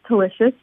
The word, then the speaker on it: Tulicious: My last name is Tulicious, pronounced like delicious.